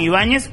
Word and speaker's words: Ibáñez: Ok, en el Facebook ahí me ubican inmediatamente Juan Ibáñez, sí, como, como se escribe mi apellido, I, B, larga, A, N, Z, Juan Ibáñez. Y está Juan Ibáñez 2, porque ya estamos repasados de ah.